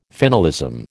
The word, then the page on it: finalism: American English